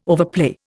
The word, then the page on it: overplay: Indian